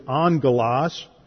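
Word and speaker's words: angelos (ἄγγελος): The Greek word is angelos, which is spelled like it has a double G in the Greek, but whenever you have a double G, the Greeks pronounced it as if it was an NG, and that's where we get our word angel.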